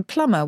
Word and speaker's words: plumber: Same, plumber. Why doesn't plumber rhyme with lumber?